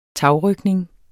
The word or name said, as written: tagrygning